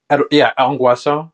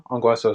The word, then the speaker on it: Ongweso: Ongweso. Ongweso is the way.